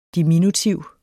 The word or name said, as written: diminutiv